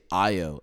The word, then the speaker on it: Ayo: No, usually, I'll say, like, if I am saying my name, I say Ayo, A-Y-O. And then I've had people be like, okay, I-O-A-Y-O. How do you pronounce that?